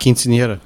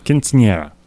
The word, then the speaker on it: Quinceañera: Quinceañera. – Quinceañera. – Quinceañera.